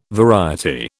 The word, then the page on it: variety: British English